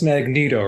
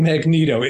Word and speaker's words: Magneto: So why is his name Magneto? It makes absolutely no sense if you look at the English language. You know, it just there's nothing to make that a long E.